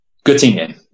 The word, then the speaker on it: Göttingen: Göttingen? – Göttingen.